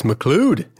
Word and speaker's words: McLeod: And there's a cartoon that illustrates this by a guy named, a cartoonist named Hugh McLeod. – That's McLeod, McLeod. He's Scottish.